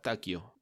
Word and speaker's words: Takio: Se escribe como Tokio, pero Takio. Reemplaza la O por una A. – Sí, T-A-K-I-O, Takio. – Sí, y con I latina, no con Y.